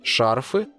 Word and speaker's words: шарфы: Кстати, в слове «шарф», как и в случае со свитером, ударение всегда на первом слоге. «Шарфы», «шарфами», «в шарфе».